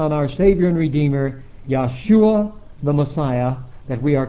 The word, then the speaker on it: Yahshua: Now Joshua, pronounced as it would be in Hebrew, since there's no J in Hebrew, would be just like the pronunciation of the J in Hallelujah, and that would be Yahshua. And therefore, to make the spelling of it more clear to those that would read it for the first time, instead of spelling it J or Y-O-S-H-U-A, we spell it Y-A-H-S-H-U-A.